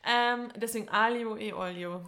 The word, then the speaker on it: aglio: Ich sag Aglio. Aglio. Nein, nein, nein, Alio. – Man spielt das G, weil das G spricht man ja im Italienischen, wie wir gelernt haben, oft nicht mit.